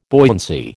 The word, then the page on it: buoyancy: American English